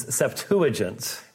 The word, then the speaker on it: Septuagint: Generally, the pronunciation is Septuagint.